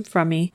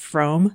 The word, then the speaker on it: Frommey: And the pronunciation of Frommey may sound odd because she's usually referred to as Lynette Frome in media, but according to Lynn herself, the correct pronunciation is Frommey.